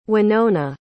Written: wynona

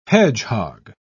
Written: hedgehog

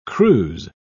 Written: cruise

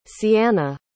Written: cianna

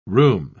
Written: rheum

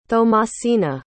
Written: thomasina

